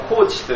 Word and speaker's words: Kocs: そして、コーチという言葉がありますね。指導者という意味を表すこのコーチという言葉がありますけれども、その語源がまた面白いですよ。このコーチという言葉の語源はですね、これハンガリー語から来ているそうなんです。ハンガリーのこれ、 KOCS と書いてコーチと読むんですけれども、コーチという町で作られた滅場所の名前が、このコーチというんですけれども、その名前に、由来しているそうです。